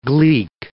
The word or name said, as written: gleek